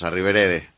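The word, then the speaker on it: arrivederci: Entonces te digo, a Riverede meto la pata. Lo he dicho bien, ¿no? – Sí. – Bueno, pues a Riverede, adiós.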